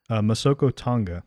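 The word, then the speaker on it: Masoko Tanga: How do you say it? – Masoko Tonga.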